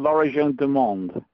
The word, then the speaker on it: L'Origine du Monde: Is it called L'Origine du Monde, the painting? – Thank you.